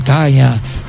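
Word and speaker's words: Kaga: Recuerden al Yamato, al Musashi, o a los portaaviones, el Akagi, el Kaya. Así se pronuncia, porque si lo pronuncio como se escribe, van a decir que soy un vulgar.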